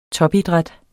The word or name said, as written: topidræt